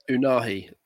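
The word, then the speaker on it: Ounahi: The Moroccan lad, is it Anouha? Is that how you say it? – Unahi.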